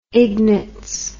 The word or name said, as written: ignatz